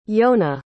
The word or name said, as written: yonah